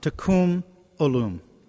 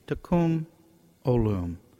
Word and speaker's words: Tikkun Olam: I believe it's pronounced something like Tikkun Olam, Tikkun Olam.